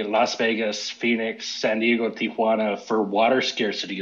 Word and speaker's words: Tijuana: where are you getting some of these additional syllables? It doesn't make any sense. Tia, like the word for aunt. Um, it's Tijuana. That's it, guys.